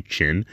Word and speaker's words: Qin: It's spelled Q-I-N, so I just say Chin, because if I'm saying it wrong, I do apologize.